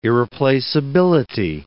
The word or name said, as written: irreplaceability